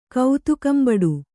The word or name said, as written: ಕೌತುಕಂಬಡು